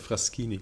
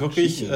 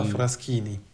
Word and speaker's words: Fraschini: Also das heißt Fraschini. Wirklich, also das CHI wird KI ausgesprochen, also Isotta Fraschini.